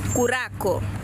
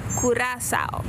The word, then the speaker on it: Curaçao: Let's start with how you should not pronounce it. Curacao, curaco, curaçao, curacao, cucarachao, curaciao. The correct way to say the name of a beautiful Caribbean island. And the world famous liquor is Curaçao.